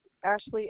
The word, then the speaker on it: Ashleigh: Ashley, A-S-H-L-E-I-G-H. – Could you spell that again? – A-S-H-L-E-I-G-H. – L-E-I-G-H? – Yes.